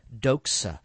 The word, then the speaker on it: doxa: It's the word doxa. Doxa. We would spell it D-O-X-A, I guess, transliterated out into English, but it's the root really of our word for doxology.